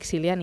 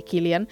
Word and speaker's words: Cillian: I el protagonista, no, el cap de la família, és en Tommy Shelby, interpretat doncs per en Cillian Murphy, Cillian Murphy, perdó, sempre li dic Cillian i es pronuncia Cillian Murphy, que és aquest actor...